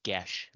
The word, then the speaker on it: geas: Is the pronunciation of G-S, the D&D spell. It's Gesh. G-E-A-S in Gaelic is Gesh. It's a Gaelic word.